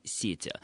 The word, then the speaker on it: сети: Вообще, на самом деле, сети это слово, которое сохраняет ударение в любых косвенных падежах. И правильным, исконным ударением было именно сети. Не является ошибкой использования другой акцентологической формы, в сети.